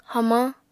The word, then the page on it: hamañ: apatani